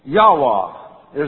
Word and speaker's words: Yahweh: Yahweh is the closest pronunciation.